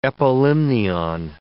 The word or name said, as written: epilimnion